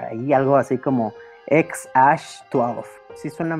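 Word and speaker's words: Æ: Que algunas fuentes dicen que se pronunciaría como un Sasha 12 pues dicen la, esa la A con la E ahí medio rara, se pronuncia Ash, pues yo digo, va a decir Sasha, ¿no?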